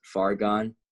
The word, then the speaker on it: Fargon: SoundCloud is Fargon, Fargon 3. – G-O-N, Fargon 3. So G-O-N. It's like, it's far gone, but it's spelled like argon gas with an F, right?